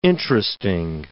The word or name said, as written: interesting